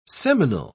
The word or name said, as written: seminal